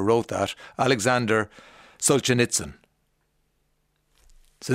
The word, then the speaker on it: Solzhenitsyn: No, this is a tricky one to pronounce. Looking for Solzhenitsyn.